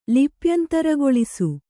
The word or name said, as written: ಲಿಪ್ಯಂತರಗೊಳಿಸು